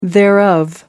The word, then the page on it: thereof: American English